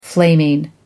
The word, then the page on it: flaming: American English